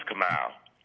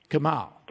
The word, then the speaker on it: Kumail: It's Kumail. – Kumail.